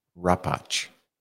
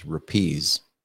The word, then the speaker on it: Rapacz: No C. The C is silent. – C is silent. Yeah. – Just Al. – No, I have to tell you one other little story. There is my father, when he was a child, they pronounced our last name Rapacz, which is the Polish name.